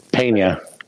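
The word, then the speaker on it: Peña: P-E-N-A. How do you say that? – Pena.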